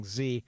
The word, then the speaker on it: Xe: They're now called Z, or XE, which most people are pronouncing Z, not to be confused with the Costas Gavras film, which is still one of the scariest movies ever made.